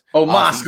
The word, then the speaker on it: Omos: You got AJ and almost going. Oh shit, I said his name wrong. – Omos going to win.